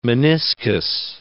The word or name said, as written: meniscus